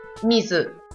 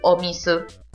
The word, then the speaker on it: mizu: Mizu. Mizu. ¿Sí? Agua se dice Mizu o Mizu. Si le pongo la O adelante, mejor. O Mizu.